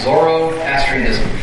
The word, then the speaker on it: Zoroastrianism: And I looked it up. That's how it's pronounced. Zoroastrianism.